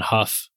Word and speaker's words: Hough: I'm on LinkedIn, Brandon Huff. Huff is spelled like H-O-U-G-H, like tough.